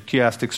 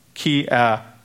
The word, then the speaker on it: chiastic: So keep that in mind whenever you hear chiastic structure, chiastic structure. So it is English, it's spelled C-H-I-A, chiastic, chiastic. So it reads chiastic,